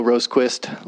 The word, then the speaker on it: Rosquist: My name is Will Rosequist. Last name is spelled R-O-S-Q-U-I-S-T.